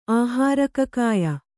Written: ಆಹಾರಕಕಾಯ

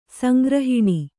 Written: ಸಂಗ್ರಹಣ

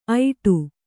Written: ಐಟು

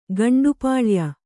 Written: ಗಂಡುಪಾಳ್ಯ

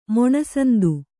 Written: ಮೊಣಸಂದು

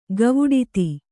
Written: ಗವುಡಿತಿ

